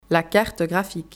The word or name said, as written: La carte graphique